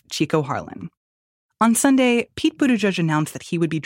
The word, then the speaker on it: Buttigieg: In Malta, they pronounce it, I was scolded many times, but I think I got it at the end. It is Buttigieg, Buttigieg, like that, Buttigieg. They kind of roll right over the first two syllables and then draw out the third, like each.